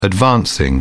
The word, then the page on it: advancing: British English